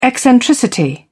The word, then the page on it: eccentricity: British English